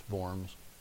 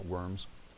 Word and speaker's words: Worms: When he stood there that day in Worms, and by the way, it's pronounced Worms, not Worms. Spelled Worms, but it's not.